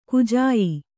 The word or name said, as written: ಕಜಿಯಾ